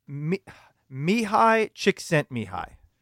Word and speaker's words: Mihai Csikszentmihalyi: It is Mihai Csikszentmihalyi. – That's right.